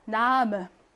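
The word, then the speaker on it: Namen: 그래서 어처럼 이렇게 발음해요. 남음. 남해도 아니고 남해도 아니고 남음. 이렇게 약하게 발음합니다.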